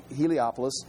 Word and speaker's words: Heliopolis: Heliopolis, where they worship the sun, in Egypt.